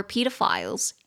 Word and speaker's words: pedophile: It was to check if there were pedophiles and terrorists。所以这边我觉得可以学的就是恋童癖患者这个字，它念作 pedophile，pedophile，或者是 pedophile，pedophile，两个发音都可以，一个是美式，一个是英式。如果我没记错的话，美式是 pedophile，pedophile，英式是 pedophile。